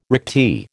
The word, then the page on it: recti: American English